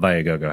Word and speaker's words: Viagogo: Yeah, actually, it goes back and forth depending on what country you're from. So, okay. Yeah, so domestically, it's called Viagogo.